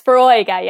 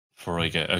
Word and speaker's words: Foróige: So it's a fada. There's a fada over the O. I'm pretty sure. I'd have to double check that now myself. There's a fada over it. Yeah. So it's pronounced Froiga.